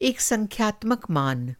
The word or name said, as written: मान